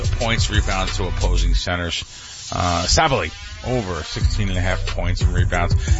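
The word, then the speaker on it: Sabally: Let's, Sabali. Am I saying it right? Sabali. – Sabali, yeah.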